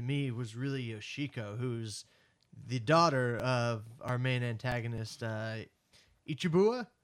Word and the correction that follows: Iwabuchi: Am I saying that right? Iwabuchi. – Iwabuchi, yeah.